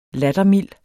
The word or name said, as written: lattermild